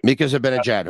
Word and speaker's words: Mika Zibanejad: Mika Zibanejad, who went seventh Ottawa, I think, or something like that.